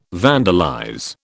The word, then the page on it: vandalize: British English